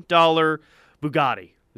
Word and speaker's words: Bugatti: Cristiano Ronaldo, he has a $3 million Bugatti. Is that how you say it? – Yes.